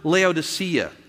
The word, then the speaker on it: Laodicea: Is this when we talk about that, we realize that we are in the church of Laodicea. See, I didn't say that right last week, I said it right this time.